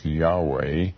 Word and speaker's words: Yahweh: The word Jehovah, or as it's sometimes pronounced, Yahweh, is found thousands of times.